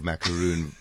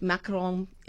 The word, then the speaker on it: macaron: So if you can use the pronunciation, which I try my best, Macron. – But I can never do it. Macron is probably the exact pronunciation. But I think if you're not so keen on maybe looking a bit, being a bit embarrassed by your pronunciation, people say macaroon.